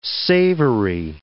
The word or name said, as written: savory